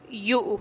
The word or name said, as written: yu'u